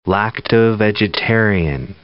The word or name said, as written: lacto-vegetarian